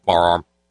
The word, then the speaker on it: firearm: And that's the correct pronunciation in Kentucky and generally in Appalachia. Firearm. It's almost only a one-syllable word, firearm. It sounds like a cyborg whose voter is stuck and can't say farm.